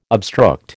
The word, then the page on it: obstruct: American English